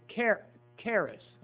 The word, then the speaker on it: charis: The Greek word is interesting for grace is charis, or charis, charis. Many times the ch is pronounced like a k, like in our word school. So I think it's charis, charis.